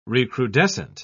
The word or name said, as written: recrudescent